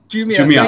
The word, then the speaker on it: Jumia: Jumia.